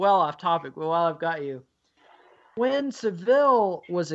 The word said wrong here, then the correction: Savile: exposed, and am I still pronouncing that wrong? Is that you're still pronouncing it wrong? Savile.